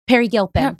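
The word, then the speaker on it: Peri Gilpin: Pierre Gilpin? – Perry Gilpin. – Perry Gilpin, excuse me. Oh, I'm thinking of somebody else. – Spelled P-E-R-I.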